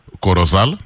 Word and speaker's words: Corozal: How do you pronounce it? Corozal? That's how you pronounce it? – Yes. Yes. That's correct.